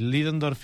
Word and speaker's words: Ludendorff: Clar, el dia 23 d'agost, Ludendorff i Hindenburg, has pronunciat molt millor tu el nom de la, Ludendorff, no?